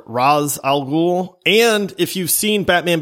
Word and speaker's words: Ra's al Ghul: Yeah, it really, because it's a comic, it's Ra's al Ghul, Ra's al Ghul, Ra's al Ghul. I've always pronounced it Ra's al Ghul.